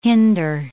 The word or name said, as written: hinder